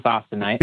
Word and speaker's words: austenite: it all becomes, how do you pronounce it again? Austenite? I say it again. – Mm-hmm. Yeah, it becomes austenite.